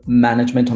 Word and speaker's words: management: So management on the first syllable, performance on the second syllable, for example.